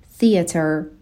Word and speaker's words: theater: به تلفظ کلمه theater دقت بکنین، دو تا صدای ت داریم ولی اولی TH هستش، بعد نوک زبونی تلفظ بشه ولی دومی همون تی هستش که ما توی فارسی هم میگیم، پس theater.